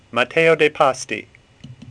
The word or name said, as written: Pasti